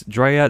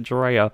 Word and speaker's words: Drea: So she goes by the name Dre. – Yeah. – Which I've written in the trivia, and then I just pronounced it Drea.